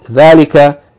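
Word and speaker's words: dhalika (ذٰلِكَ): Note, dhalika is pronounced as though there's an aleph elongating the fatha of the first letter, dhal. So it should be read, dhalika, not dhalika, dhalika, even though it is written without the aleph.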